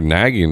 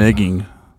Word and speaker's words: negging: I think that's called negging. – Negging? – Uh-huh. – Is it? – Yeah. – I never heard a term. I've heard nagging before. – No, negging. – Negging? – Uh-huh. N-E-G-G-I-N-G.